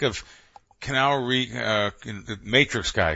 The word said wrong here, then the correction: Keanu: Am I saying his name right? Keanu Reeves? – Keanu Reeves. – Keanu. That's what you say, Keanu.